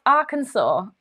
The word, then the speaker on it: Arkansas: It's actually pronounced Arkansas.